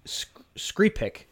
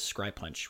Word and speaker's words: Skrypuch: It's Stolen Girl by Marcia Skrypek, is I believe how it's pronounced. But if you're looking to spell it, it's Skrypuch. But I actually listened to her pronounce it, and I believe that's how it was, or it's closer to that than it is Skrypunch, which is how I've been pronouncing it.